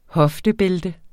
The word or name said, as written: hoftebælte